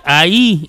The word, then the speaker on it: ahí: Y luego la otra dice ahí y lleva acento en la I, la H va intermedia.